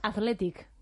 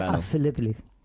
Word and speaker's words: Athletic: De verdad, ¿cómo se dice? ¿Aleti o Athletic? – Vale, pero es que en inglés es Athletic. – Athletic, claro. – La TH es Athletic.